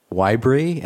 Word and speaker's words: Wibree: I will accept Wibree as a pronunciation.